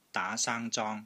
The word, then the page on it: DAA2 SANG1 ZONG1: Cantonese